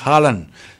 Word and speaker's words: Haaland: Y Emmanuel Holland, que ¿por qué le digo Holland? Bueno, es que sí se pronuncia Erling Haaland. Es como Erling Haaland, pero con el pelo moreno.